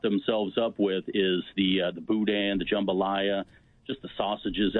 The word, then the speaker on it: boudin: Well, and by the way, boudin is a silent N.